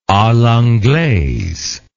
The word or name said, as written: a l'anglaise